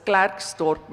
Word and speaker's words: Klerksdorp: I don't call it Klerksdorp, it's Klerksdorp, Mensa.